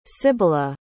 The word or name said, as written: sibila